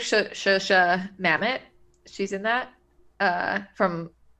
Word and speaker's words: Zosia: I've been calling her Zosia for a long time, but I think it's Shosha.